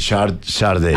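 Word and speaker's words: Sade: No sé cómo se pronuncia. – Sharday. – Ah, Sharday, sí. – Sí, señor. Oye, Beatriz, otra vez, Smooth Operator de Sharday.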